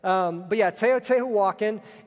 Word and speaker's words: Teotihuacan: It's the Teotihuacan. That's it right there. Yeah, thank you. So, Teotihuacan.